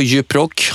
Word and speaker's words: Djuprock: Vad pysslar du med på Djuprock? Har jag rätt uttal till att börja med? Det låter jättebra tycker jag.